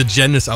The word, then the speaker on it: Janiz: Das Einfachste, glaube ich, tatsächlich googeln und im Kopf behalten, dass wir Janis ausgesprochen werden. Und dann findet ihr alles, was es über uns zu wissen gibt. – Ja, nur Aussprache bei Google immer schwierig. Schreibweise ist wichtiger. J-A-N-I-Z.